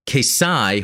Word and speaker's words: Xi: And the answer was it's Greek, Kesai, hard X sound. Don't ever trust the pronunciations in a dub. It's not intended to be Z Zai, or Chi.